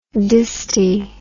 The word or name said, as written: disti